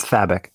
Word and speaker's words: Fabok: What is it? – It's Fabok. – Fabik.